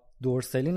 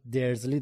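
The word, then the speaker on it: Dursley: علاوه بر هاگوارتس کلی دیگه از اسامی کتاب هم تغییر کرده، تلفظا دقیقا مثل نسخه بریتانیاییه، یعنی ما دیگه هرمیون نداریم، هرمیونی داریم، یا دورسلی نداریم، درزلی داریم، و کلی اسامی دیگه.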